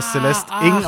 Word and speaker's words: Celeste Ng: Die aber Celeste Ng ausgesprochen wird.